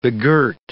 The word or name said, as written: begirt